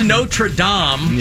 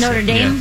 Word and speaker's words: Notre Dame: Well, it is Notre Dame. – Yeah, not Dame. – Notre Dame. – Say that for the... I like Dame. – I say it the way Americans say it.